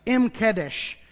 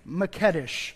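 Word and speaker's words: M'kaddesh: And I just had a gentleman that is Jewish tell me how to say it, and it's Mekedesh. I was saying, Google told me it's Mekedesh. That's not true at all, so thank you, Google, but it's Mekedesh.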